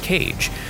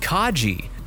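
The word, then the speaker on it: Kage: Romero's like, no, dude, it's pronounced Kaji.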